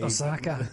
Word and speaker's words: Osaka: That there's a state of emergency um, in Osaka. Yes. Did I pronounce that right? Oh, thank God. Uh, you're my, you're the Japanese. Osaka.